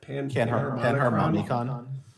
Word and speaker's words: Panharmonicon: as a Panharkana, how do you say that card? – Panharmonicon.